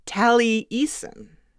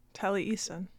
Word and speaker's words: Taliesin: Yeah, he says it's pronounced Taliesin. – Taliesin. – That it's Welsh, we know, and that it's originally had a hyphen in it.